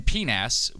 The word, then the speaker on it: PNAS: So in PNAS, which if you don't know, I'm not mispronouncing penis.